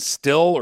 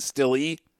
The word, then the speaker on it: Stille: So, and then the other guy you got to look, I believe it's pronounced Ben Still or Ben Stille, S-T-I-L-L-E.